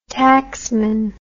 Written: tacksman

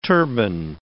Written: turban

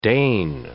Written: Dane

